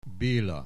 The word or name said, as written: Bela